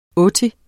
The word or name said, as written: otti